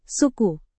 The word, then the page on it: suku: Indonesian